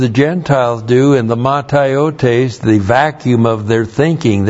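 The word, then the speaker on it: Mataiotes (ματαιότης): Insist on it in the Lord that you must no longer live as the Gentiles do in the mataiotes of their mind. That's the Greek word there. Looks like that in the Greek. M-A-T-A-I-O-T, long E-S.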